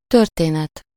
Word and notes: történet: /ˈtørteːnɛt/

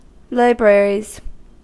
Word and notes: libraries: American English; [ˈlaɪ.ˌbɹɛɹ.iz]

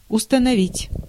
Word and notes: installer: [ɛ̃s.ta.le]; France (Paris)

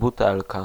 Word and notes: bouteille: /bu.tɛj/; France

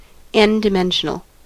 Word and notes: n-dimensional: American English

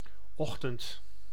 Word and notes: ochtend: [oχ.tɛnt]; /ˈɔxtənt/

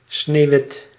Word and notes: sneeuwwit: /sneˈʋɪt/

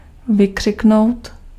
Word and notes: crier: France; [kʁi.je]

